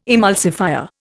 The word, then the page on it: emulsifier: Indian